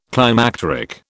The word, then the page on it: climacteric: British English